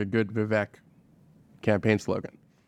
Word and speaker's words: Vivek: It's even better because his name is pronounced Vivek.